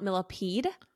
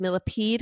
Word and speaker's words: Millepied: um, I incorrectly pronounced Benjamin's last name as Millipede because that's how it's spelled. – Right. Um, but apparently it's French and it's actually pronounced millipede.